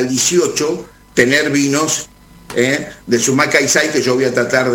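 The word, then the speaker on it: Sumak Kawsai: Te lo deletreo, S-U-M-A-K, acá. – Sí, Sumac. – Sumac, K de kilo, A-W-S-A-I, Calçay. Así se pronuncia. Sumac Calçay, en lengua cacán, quiere decir buen vivir.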